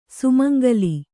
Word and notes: ಸುಮಂಗಲಿ: kannada

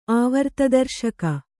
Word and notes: ಆವರ್ತದರ್ಶಕ: āvartadarśaka

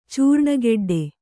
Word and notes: ಚೂರ್ಣಗೆಡ್ಡೆ: cūrṇa geḍḍe; Kannada

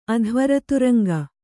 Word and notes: ಅಧ್ವರತುರಂಗ: Kannada

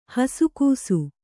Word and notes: ಹಸುಗೂಸು: Kannada

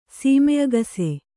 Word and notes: ಸೀಮೆಅಗಸೆ: sīme agase

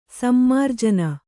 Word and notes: ಸಮ್ಮಾರ್ಜನಿ: Kannada

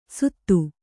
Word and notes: ಸುತ್ತು: Kannada

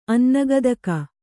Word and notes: ಅನ್ನಗದಕ: Kannada

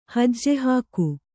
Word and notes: ಹೆಜ್ಜೆಹಾಕು: hejje hāku

